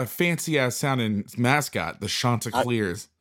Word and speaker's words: Chanticleers: Chanticleers.